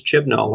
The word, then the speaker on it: Chibnall: I think she's going to be fine, and I think it's all going to lie on Chris Chibnall.